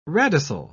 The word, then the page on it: radicel: British English